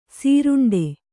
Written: ಸುರುಂಟು

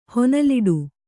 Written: ಹೊನಲಿಡು

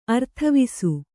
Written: ಅರ್ಥವಿಸು

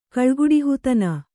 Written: ಕಳ್ಗುಡುಹಿತನ

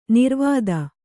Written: ನಿರ್ವಾತ

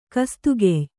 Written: ಕಸ್ತುಗೆಯ್

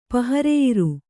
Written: ಪಹರೆಯಿರು

